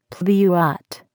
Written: pluot

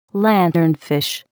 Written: lanternfish

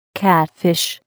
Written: catfish

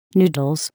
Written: noodles

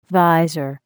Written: visor

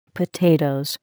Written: potatoes

